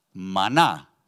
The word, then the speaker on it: manna: Manna, in Hebrew, is pronounced mana.